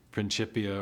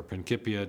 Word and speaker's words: Principia: the Principia or Principia, depending on your preferred Latin pronunciations.